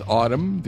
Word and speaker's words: autumn: And with autumn, the N is silent.